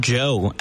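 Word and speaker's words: Djo: That was End of the Beginning by DGO, Joe, as I recently discovered his name is pronounced.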